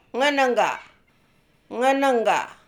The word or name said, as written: Ngenengga